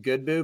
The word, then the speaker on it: Godbout: One in particular, his name's Jonathan Goodboo, but it's spelled Godbout.